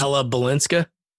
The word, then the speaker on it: Ella Balinska: Ilea Balsink, I think that's how you pronounce her name. She's Ella Balinska.